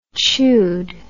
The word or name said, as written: chewed